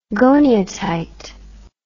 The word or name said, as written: goniatite